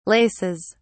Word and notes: laces: British English

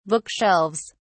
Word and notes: bookshelves: British English

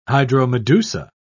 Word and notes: hydromedusa: British English

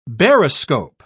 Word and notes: baroscope: British English

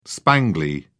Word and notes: spangly: British English

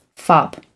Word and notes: fop: American English

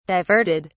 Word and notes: diverted: British English

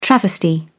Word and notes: travesty: British English

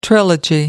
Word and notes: trilogy: American English

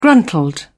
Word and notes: gruntled: British English

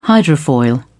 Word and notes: hydrofoil: British English

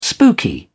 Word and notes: spooky: British English

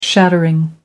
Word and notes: shattering: American English

